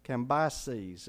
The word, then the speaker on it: Cambyses: Cambyses is how you pronounce it. C-A-M-B-Y-S-E-S.